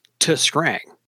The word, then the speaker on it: Tuskrang: It is pronounced Tuskrang. Emphasis on the T, right?